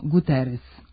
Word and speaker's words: Guterres: Antonio Guterres. Zato što se, prema pravilima transkripcije sa portugalskog, koje su obrađeno u pravopisu, portugalsko krajnje S prenosi kao S, a ne kao Š.